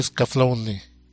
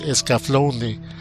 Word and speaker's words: Escaflowne: Vamos con la siguiente serie, otra serie vieja, Tenku no Scaflowne. Que lo traducen como The Vision of Scaflowne o en su versión doblada en Latinoamérica, La Visión de Scaflowne, que algunos lo pronuncian erróneamente como Scaflown, pero es Scaflowne.